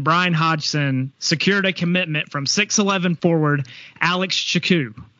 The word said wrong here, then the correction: Tchikou: I said that right, correct? Tchikou. Tchikou. Tchikou. Okay.